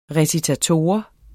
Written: recitator

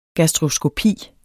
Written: GASTROSKOPI

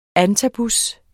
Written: antabus®

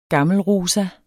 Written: gammelrosa

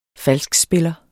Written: FALSKSPILLER

